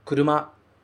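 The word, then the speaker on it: kuruma (車): Ele continua significando carro, mas quando ele vem sozinho, ele é pronunciado kuruma, kuruma.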